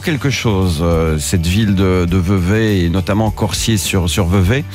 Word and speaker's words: Vevey: Alors, je pensais que ça se prononçait Vevey, je l'ai toujours dit comme ça. Mais ça se prononce donc Vevey, c'est un camarade suisse qui me l'a dit.